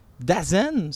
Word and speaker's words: DAZN: C'est Dazen, ça s'écrit D-A-Z-N.